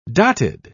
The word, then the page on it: dotted: British English